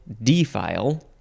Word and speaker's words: defile: And you could also emphasize the first syllable, defile.